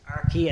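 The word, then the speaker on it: Aki: Arky, as I'm saying, as William was told by Peter himself to pronounce it, although the Connacht press officer, Brendan, today was just saying, I thought he said Arky to me, so trust us, we're working on this, but we're pretty confident it's Arky.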